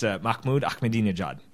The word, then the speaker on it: Mahmoud Ahmadinejad: I'm fairly certain it's Mahmoud Ahmadinejad. – No, I think you're right.